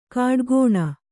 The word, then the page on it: ಕಾಡುಕೋಣ: kāḍu kōṇa